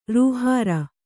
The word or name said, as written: ರೂಹಾರ